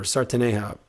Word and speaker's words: sertaneja: Next genre is the música sertaneja, or sertaneja. It's Portuguese pronunciation, and it's a musical style that had its origins in the countryside of Brazil in the 1920s.